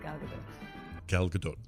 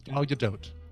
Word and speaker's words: Gal Gadot: A lot of the people call me Gal, like different variations, but I totally get it because it's hard to pronounce. But yeah, my name is Gal Gadot. – Gal Gadot. – Okay. – Okay, it is Gadot.